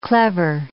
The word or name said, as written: clever